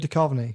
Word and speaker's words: Duchovny: David Duchovny, is it? – You nearly had it. You put an extra syllable at the end for no reason. – David Duchovny.